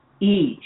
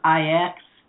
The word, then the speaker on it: Ix: And the solar glyph is Ish, which is IX, and a lot of people pronounce it Ix, and it is all about setting your intentions, heart and mind together, and I have my heart mind.